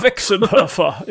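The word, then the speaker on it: Westenhöfer: Westenhofer. I hope I pronounced the name well. – Westenhofer, yeah, okay. – Westenhofer. – Westenhofer. – Yes, something like that.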